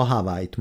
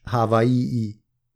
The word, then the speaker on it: Hawaii: A szigetlakók udvariasan kijavítanak bennünket, ha Hawaii-t mondunk. A helyes kiejtés Hawaii-i, majdnem szótagolva és az i betűt külön kiejtve, ugyanígy külön kell kiejteni szinte minden egymást követő magánhangzót, tehát nem Kauai, a legnyugatibb sziget neve, hanem Kauai.